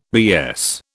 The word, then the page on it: BS: British English